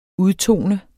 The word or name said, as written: udtone